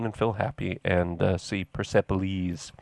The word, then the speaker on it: Persepolis: Persepolis? – Yeah, I say Persepolis. – Persepolis.